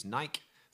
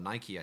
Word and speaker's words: Nike: Nike, for example, or Nike, I think, as they say in America, which is probably the more accurate pronunciation anyway, I think that those under 34 make up 43% of their business.